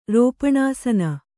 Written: ರೋಪಣಾಸನ

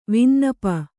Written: ವಿನ್ನಪ

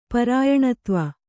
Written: ಪರಾಯಣತ್ವ

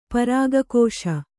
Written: ಪರಾಗಕೋಶ

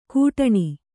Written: ಕೂಟಣಿ

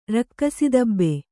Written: ರಕ್ಕಸಿದಬ್ಬೆ

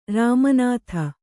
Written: ರಾಮನಾಥ

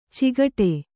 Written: ಚಿಗಟೆ

